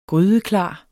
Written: grydeklar

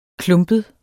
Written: klumpet

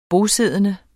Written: bosiddende

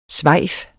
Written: svejf

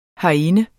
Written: herinde

